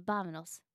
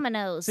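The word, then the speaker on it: vámonos: Well, it's like a soft B, vamanos. – Oh, damn it. I get that wrong all the time. – Vamanos.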